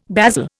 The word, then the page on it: basil: Indian